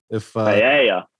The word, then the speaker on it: paella: Paella, which I'm butchering as well, or paella. – Paella.